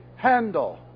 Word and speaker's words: Handel: Can you pronounce it correctly? It is not Handel, and it's not Handel, but as any good German knows, the A in this name is like the sound in the word let. Or letter. Say eh. Now say Handel.